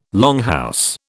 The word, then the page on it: longhouse: British English